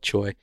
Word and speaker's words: Tsui: Um, his name is actually spelled T S U I, but it's spelled Choi.